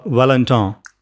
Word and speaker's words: Valentin: You say Valentin, which is difficult because those vowels don't exist in the English language. – And Valentin.